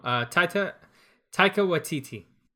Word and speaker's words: Taika Waititi: Oh, Taika Waititi.